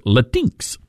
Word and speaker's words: Latinx: By the way, I didn't realize I had been mispronouncing that word all this time. Because I've been saying Latinx or Latinx. L-A-T-I-N-X, right? You would think Latinx. But that's not the correct pronunciation. It's Latinx.